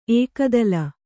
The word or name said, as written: ಏಕತಾಲ